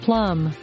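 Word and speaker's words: plumb: Obviate and plum. – Plum with the silent B.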